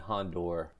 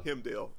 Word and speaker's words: Heimdall: So following that opening scene, I was unhappy to see Hondor. – Heimdall? – Bit the dust. I'm sorry, am I saying it wrong? – Heimdall.